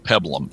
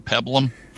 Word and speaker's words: peplum: Now, so that is my hands down favorite peplum. Is it, am I saying it right? Peplum. – It's peplum. P-E-P-L-U-M. – Peplum. – And I may be. I mean, that's always how I've heard it pronounced.